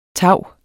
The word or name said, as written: tau